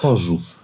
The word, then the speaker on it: Chorzów: Horsuf. So, das hört sich an nach Vollsuff, ehrlich gesagt, nicht nach Horsuf. Ja, so ähnlich wird das ausgesprochen.